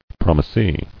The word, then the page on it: Promisee: prom·is·ee